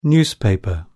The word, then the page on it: newspaper: British English